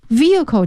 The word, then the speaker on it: vehicle: Vehicle 这个词请大家注意一下，它的拼写是 V-E-H-I-C-L-E， Vehicle H 是不发音的，就是机动车的意思。